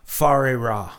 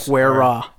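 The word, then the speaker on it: Whare Ra: I think the last one that can kind of claim something close was probably Fare Ra. So it's spelt W-H-A-R-E, Yeah, it looks like Werra. – It looks like Werra, but it's Farera because it's a Aboriginal term.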